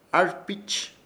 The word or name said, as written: ARPIPICH